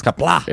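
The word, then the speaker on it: Qapla': It's Kapla.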